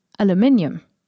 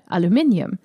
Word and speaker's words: aluminium: May I just refer you to aluminium, which I am absolutely convinced is how you say that word, aluminium. Now, if you're a listener in the USA, you're gonna turn your head and say what? It's aluminum. It is obviously not aluminium.